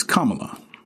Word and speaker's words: Kamala: It is not Kamala. It is Kamala. It is Kamala Harris. Kamala Devi, or Devi Harris is her full name, and she was born in October 1964, the 20th day of October.